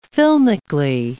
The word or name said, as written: filmically